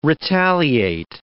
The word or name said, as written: retaliate